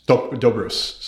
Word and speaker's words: Dobrus: So, Athelia, last name pronounced Dokus? Dobrus, sorry. – Yes, it's Do like Jindo and Bruce like Brusula.